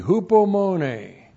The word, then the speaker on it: hupomone: H-U-P-O-M-O-N, long E, hupomone.